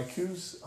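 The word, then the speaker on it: Coues: And my coos hunt, well, they pronounce it cows in a lot of places.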